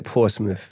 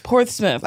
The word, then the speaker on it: Portsmouth: It's more like Portsmouth. – Portsmouth. – Like an IFF at the end.